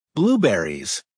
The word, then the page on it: blueberries: American English